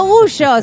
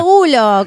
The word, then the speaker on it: Gulo: Y bien dicho, no Gullo, sino Gulo, como se pronuncia.